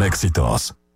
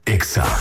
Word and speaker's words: Exa: No se dicen éxitos, se dice Exa. – En todas partes. – En todas partes. Ponte Exa FM.